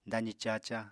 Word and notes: dazi caca: Apatani

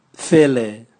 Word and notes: vele: Raga

